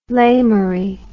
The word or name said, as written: lamerie